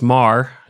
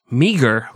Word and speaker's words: Meagher: And for our listeners who don't speak Gaelic or aren't from Ireland, it's not spelled M-A-R like you think it would be, and it's not pronounced meager like you think it would be, but it is spelled M-E-A-G-H-E-R.